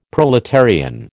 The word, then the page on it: proletarian: American English